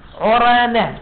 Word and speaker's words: عرانة: عرانة بضم العين عو المهملة يعني مو غين عين مهملة يعني ما بيها نقطة وفتح الراء عرا والنون عرانة